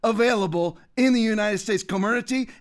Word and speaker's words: Comirnaty: Co- Comer, what's the real way to pronounce it? Co- Co what? Comirnaty. It's like Comirnaty, the one that the, the vaccine.